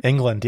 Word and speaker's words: Englund: He saw Robert Engold. Is that how you say his name? – Englund, yeah. Englund. I think.